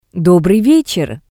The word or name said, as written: Dobrii vetser